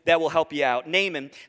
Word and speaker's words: Naaman: Our whole service today is going to be looking at this case study of a guy named Naaman. Would you say his name with me? Naaman.